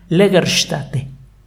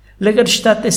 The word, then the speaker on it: Lagerstätte: Se llama Legerstätte se escribe Lager, es una sola palabra, luego sigue la letra S, T, A, T, T, E. La última A lleva diéresis arriba. Lagerstätte significa algo así como lugar de almacenamiento, es un término genérico en alemán.